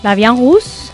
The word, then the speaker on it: La Vie en Rose: Y los eventos que os traemos en Alicante Capital es sobre el espectáculo, a ver si lo digo bien, Le Vie en Rosé. – La Vie en Rose. – Eso es.